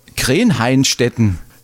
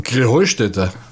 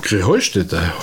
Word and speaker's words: Krähenheustätte: Ja, ihr saget immer Krähenheinstetten. Und dabei heißt es doch Krähenheustätte, oder wie ist es? – Krähenheustätte. – Ja, genau, und das die Betonung auf dem Heu. – Krähenheustätte, Heustätte, ja.